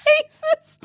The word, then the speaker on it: Chasez: How's it spelled? It's spelled C H A S E Z. Chasez. That's how it's spelled. It's literally spelled Chase with a Z.